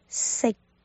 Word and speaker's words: 食: 这个音呢叫做" 食"，食物的" 食"，粤语是 "sik sik sik sik"，尾音有 "k" 的意思。但是个未爆破的音，发音结束的时候呢，舌根要轻轻的抵着上颚，从而截住气流。